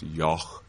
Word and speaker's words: yogh: That GH spelling was originally, I believe it's pronounced Yoch, Y-O-G-H, and words like night were pronounced nicht, and ought, I imagine, was ocht.